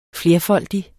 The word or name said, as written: flerfoldig